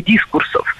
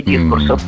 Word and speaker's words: дискурс: Ну вот в академическом поле это бы назвали изменение дискурсов или различие дискурсов. – Не дискурс, а дискурс. Ударение в этом слове всегда ставим на второй слог. И при склонении ударение не меняем.